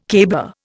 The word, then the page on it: caber: Indian